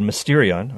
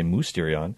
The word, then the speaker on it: Mysterion: He's the author of the upcoming book, which you can now pre-order, called Mysterion, or I should probably say Mousterion, but it's spelled Mysterion, The Revelatory Power of the Sacramental Worldview.